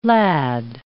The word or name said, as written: lad